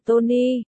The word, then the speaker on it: Toni: Tên là Tony. T-O-N-I. Bạch Cẩn Nhất sửa cho cậu. Phát âm của cậu sai rồi. Đọc lại đi.